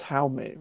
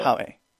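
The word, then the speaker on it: Jaume: We talked about how I how did we agree on the pronunciation on this name? Black Adam director. I think it was Haume, right? Haume Colette Serra. – Howie.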